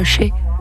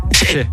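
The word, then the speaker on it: Shay: Ça se prononce pas Shai. – Chez. – Chez.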